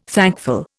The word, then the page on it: thankful: Indian